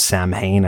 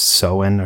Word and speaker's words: Samhain: So the night of October 31st, they celebrated this thing, which a lot of people pronounce as Samhain. I think it's technically pronounced Samhain or something like that, but I'm just going to call it Samhain.